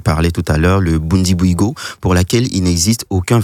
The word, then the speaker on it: Bundibugyo: L'épidémie est provoquée par le virus Ebola du type Bundibugyo.